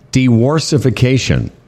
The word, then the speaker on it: diworsification: and something called diworsification.